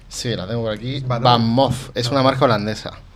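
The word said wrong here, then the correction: VanMoof: Van Moof, o Moof, como se pronuncia.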